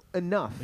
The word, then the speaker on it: enough: Is that pronounced F? – Yeah, enough.